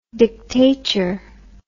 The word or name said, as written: dictature